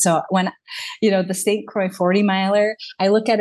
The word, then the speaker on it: St. Croix: Is it St. Croix? – Yeah. The St. Croix, 40 miler winter ultra.